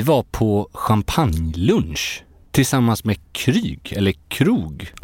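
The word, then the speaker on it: Krug: Då, det är alltså en krog. – För att få till uttalet så ska man tänka alltså som svenska krog, alltså ett krogbesök. – Mm. – Det är där någonstans man ska sikta.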